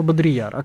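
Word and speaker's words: Baudrillard: Жана вот этого Бодрияра. А как правильно? Да. Бодрияр? Жан Бодрияр. Бодрияр.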